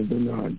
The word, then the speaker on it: Bernard: Dr. Bernard Johnson. Amen. Bernard is spelled B-E-R-N-A-R-D.